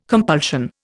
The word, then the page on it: compulsion: Indian